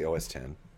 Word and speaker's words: OS X: And I believe it is available for many different operating systems, Windows, Linux, and OS 10 or OS X. How do you say that? How do the Apple people say it? – Oh, I usually say OS 10.